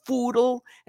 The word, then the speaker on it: Phoodle: It's called Foodle, and it's spelled P-H-O-O-D-L-E. And it is aimed at foodies, chefs, amateur cooks, and all the responses have to do with food.